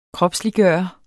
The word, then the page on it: kropsliggøre: [ -ˌgɶˀʌ ]